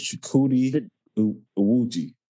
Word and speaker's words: Chukwudi Awuji: I share with you in there with, dang it, I just looked up how to say his name. I'm going to get it right. His name is Chukwudi Awuji, the High Evolutionary. That's his name, Chukwudi.